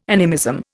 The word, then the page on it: animism: Indian